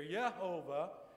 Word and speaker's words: Jehovah: Jehovah is the right pronunciation of that.